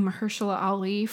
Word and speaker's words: Mahershala Ali: Best Supporting Actor, I am guessing Mahershala Ali for Moonlight.